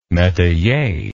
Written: metayer

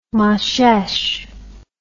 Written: Moshesh

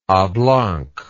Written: ablanc

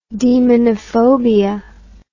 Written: demonophobia

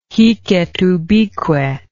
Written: hic et ubique

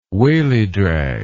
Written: wallydrag

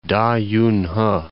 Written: Da Yunhe

